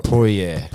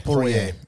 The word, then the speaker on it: Poirier: Dustin Poirier. Poirier, yeah.